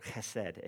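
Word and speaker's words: chesed (חסד): You can even say it like you have something in your throat, chesed, if you want, okay?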